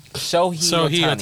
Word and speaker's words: Shohei: Shohei Ohtani.